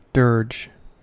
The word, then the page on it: dirge: American English